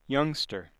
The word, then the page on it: youngster: American English